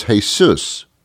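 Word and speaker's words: Jesús: But they don't pronounce the J as a Jesus. They do it as an H. So he was Jesus.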